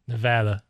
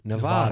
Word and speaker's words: Nevada: For example, the state where Las Vegas is, how do you pronounce it? – Nevada. – That is actually correct. Well, but I think more people who do not live in Nevada say Nevada.